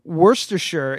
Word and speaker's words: Worcestershire: It Worcestershire is pronounced like Worcester.